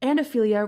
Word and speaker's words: Ophelia: And Ophelia rhymes with things like Celia.